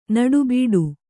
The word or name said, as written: ನೋಟಬೇಟ